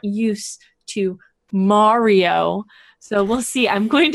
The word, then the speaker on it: Mario: See, look, I'm saying it properly. Mario.